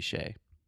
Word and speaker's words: Hsieh: So I think that we don't, like, he would not pronounce it as Shay, but most Americans say Tony Hsieh.